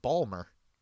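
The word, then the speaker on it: Baltimore: You said Balmer. – Balmer. – You did not pronounce the T.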